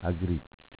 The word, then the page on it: Agricc: Dialetto